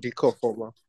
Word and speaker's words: Dika Ofoma: His name is Dika Ofoma. Did I pronounce that correctly? – Yes, you got it right. – Perfect. – Dika Ofoma. Yeah.